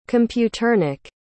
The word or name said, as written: computernik